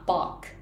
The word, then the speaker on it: バッハ: 作曲家のバッハって英語ではバックって発音するんですね